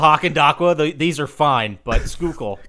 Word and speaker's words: Schuylkill: I also have not convinced my girlfriend that the word is actually pronounced Schuylkill.